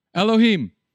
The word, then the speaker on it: Elohim: Starts with the L, rhymes with Ohim. Elohim.